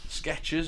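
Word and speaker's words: Skechers: There's no ease And it's spelled F-E-B-R-E-Z-E. – Febreze. – Febreze. It's the Febreze. Sketches has got no T in it. It's just, it's a ch.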